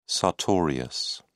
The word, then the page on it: sartorius: British English